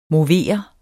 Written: movere

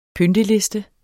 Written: PYNTELISTER

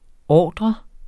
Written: ordret

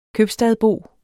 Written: købstadsbo

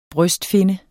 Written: brystfinne

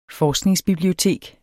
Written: forskningsbibliotek

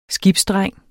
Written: SKIBSDRENG

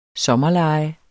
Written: sommerlege

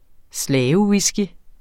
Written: slavewhisky

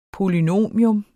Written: polynomium

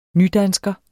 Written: nydansker

